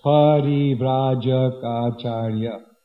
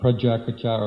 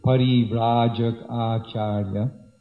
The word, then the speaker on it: Parivrajakacharya: It tends to get slaughtered in the Jai Om prayers, especially this word, Parivrajakacharya. Seven syllables, Parivrajakacharya. Not Prajakacharya or whatever. Pari means around. Vrajaka means to go around. And Acharya. So Parivrajakacharya. And also Ashtotara, Shata.